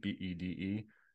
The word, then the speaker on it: Bede: Bede.